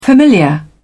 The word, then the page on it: familiar: British English